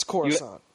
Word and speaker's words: Coruscant: It's Coruscant.